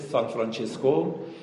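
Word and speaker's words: San Francesco: یه عارف خیلی بزرگ و جانانه در دنیای مسیحی وجود داره به نام سان فرانسوای آسیزی که تلفظ ایتالیاییش میشه سان فرانچسکو و تلفظ انگلیسیش میشه سان فرانسیس، و چون اهل آسیز بوده، آسیز ایتالیا، بهش میگن سان فرانسیز آسیزی یا سان فرانسوای آسیزی یا سان فرانچسکوی آسیزی.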